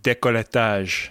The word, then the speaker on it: décolletage: Yeah, got to get that décolletage out. – Is that the right way to say that word? – I'm assuming it's from the French, and they would pronounce it décolletage.